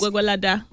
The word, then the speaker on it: Gwagwalada: Gwagwalada. – Thank you. We know they go. – Gwagwalada.